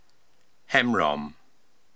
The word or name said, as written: Hemrom